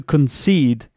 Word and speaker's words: concede: To concede, concede. Again, there is a schwa sound, concede. And that means that this syllable isn't stressed. The following syllable, seed, the seed syllable is stressed instead.